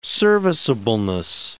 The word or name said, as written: serviceableness